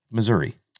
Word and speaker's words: Missouri: There's no A. Mm-mm. It's an I. It's Missouri. – Yeah. – Missouri. – It's like spelled with a dash UH on the end.